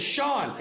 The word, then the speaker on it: Sean: Like, hi, Mom, my name is Sean, in case you don't have this number saved. – It's spelled S-E-A-N.